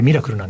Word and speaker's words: Mirakl: MIRAKL と書いてミラクルなんですが、